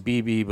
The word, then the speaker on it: Bebe: I looked everywhere for the pronunciation of their names because her name is a nickname, B-E-B-E. I didn't know if it was Bebe or apparently it's BB, but, you know, they didn't say how Louis or Louis is pronounced.